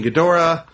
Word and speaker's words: Ghidorah: It's different pronunciations. – Okay, fair. Ghidorah, I just want to make sure I wasn't getting it wrong. – Ghidorah, Ghidra, Ghidorah all work yeah.